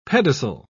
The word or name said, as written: pedicel